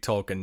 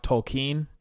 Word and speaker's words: Tolkien: I'm a pretty big Tolkien nerd, and everything, excuse me, it's actually pronounced Tolkien.